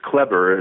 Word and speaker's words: Kleber: Well, actually, the way my name is pronounced is Kleber. It's the Americanization of his name, which was Kleber.